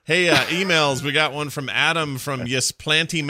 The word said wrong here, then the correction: Ypsilanti: Michigan, or how do you say it? YP. – Oh, Ypsilanti. – Ypsilanti.